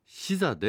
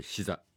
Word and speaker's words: SZA: シザでグッドデイズです。 SZA と書いてシザです。